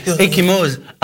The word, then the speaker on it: Ecchymose: Ecchymose. A-C-C-H-I-M-E-O-S-E. – Allez, vas-y. Très bien prononcé.